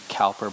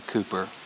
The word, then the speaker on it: Cowper: We spell it Cowper. Some Americans say Cowper, but the Brits say Cooper.